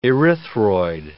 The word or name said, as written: erythroid